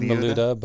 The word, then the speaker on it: Miluda: I've typically said Miluda, but yeah.